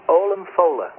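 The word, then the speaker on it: Ollamh Fodhla: You don't pronounce the D in Fola. It looks like Olam Fodla. But in Gaelic, they don't pronounce the D, so it's Olam Fola.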